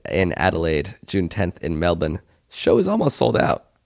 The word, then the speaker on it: Melbourne: We just booked an Airbnb in Melbourne.